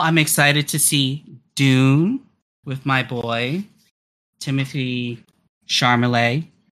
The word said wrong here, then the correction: Chalamet: Am I pronouncing his name right? – Timothy Chalamet.